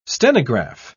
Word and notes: stenograph: British English

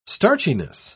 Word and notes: starchiness: British English

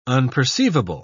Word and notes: unperceivable: British English

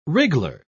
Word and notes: wriggler: British English

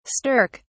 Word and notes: stirk: British English

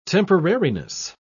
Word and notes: temporariness: British English

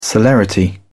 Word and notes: celerity: British English

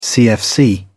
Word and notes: cfc: British English

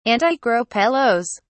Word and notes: antigropelos: British English

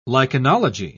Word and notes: lichenology: British English

